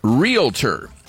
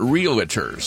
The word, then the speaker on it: realtor: It's realtor. There's only two syllables, not realtor.